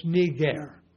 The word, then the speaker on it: Niger: if we pronounce this from Greek, it's Niger. Yeah. I in Greek, or iota in Greek, which is the equivalent of our I, always has like a long E sound.